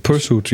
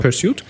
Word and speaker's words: Pursuit: Es geht um den Film Pursuit. Spricht man es auch? – Ne? Pursuit, ja. – Pursuit.